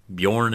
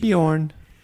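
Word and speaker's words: Björn: And if you want to count Bjorn as one syllable, I challenge you. – Bjorn.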